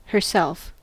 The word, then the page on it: herself: American English